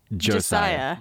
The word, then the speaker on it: Josiah: It's Josiah.